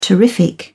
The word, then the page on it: terrific: British English